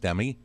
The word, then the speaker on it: STEMI: It's a recognition for, I believe that would be pronounced STEMI.